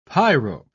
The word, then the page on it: pyrope: British English